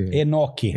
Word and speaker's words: Enoque: E sobre, aí desculpem a pronúncia, Renote e Elias, eles teriam sido levados. – Escreve isso. Enoque. Enoque, né? E Enoque.